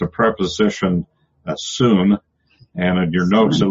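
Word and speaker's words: syn: It sounds a little more like S-O-O-N, soon. It would be a long U, kind of a soon sound. I guess it probably should put it soon, make it sound it out, but make a long U of it anyway. But it's soon.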